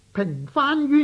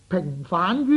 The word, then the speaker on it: 平反: 我们在报纸上时常会看到平番冤狱的字句，有些人呢每每直读成呢平反冤狱。其实呢反字在这处呢是读平声，不是读上声的。